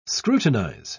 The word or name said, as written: scrutinize